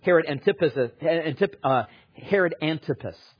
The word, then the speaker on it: Herod Antipas: Herod was Herod Antipas. That's how you say it.